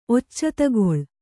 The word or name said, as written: ಒಚ್ಚತಗೊಳ್